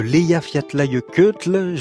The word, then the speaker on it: Eyjafjallajökull: Nous étions en 2010, éruption de l'Eyjafjallajökull. Je l'ai bien prononcé. – Parfait.